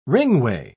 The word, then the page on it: ringway: British English